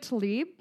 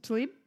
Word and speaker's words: Tlaib: Rashida Tlaib. – Yes. – Oh, shit. Did I say it right? – Yes. – Tlaib? – Yeah.